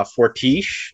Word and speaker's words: Fortiche: The animation is done by Fortiche. I'm pretty sure that's how you pronounce it, because they're a French studio.